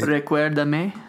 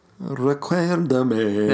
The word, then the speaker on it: Recuérdame: How do you say this one? – What? – The re... – Recuérdame. – Yeah, there you go. – It means remember me. – Recuérdame. – That's exactly it.